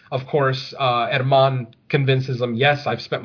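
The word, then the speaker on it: Germán: Our EVP expert's name is Herman Domingo, spelled G-E-R-M-A-N. So it looks like German, but it's Spanish, so it's pronounced Herman.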